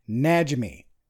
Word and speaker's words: Najimy: Najimy.